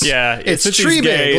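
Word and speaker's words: Triebig: Since he's, I think I've mispronounced it too, but it's Treberg, like a big tree.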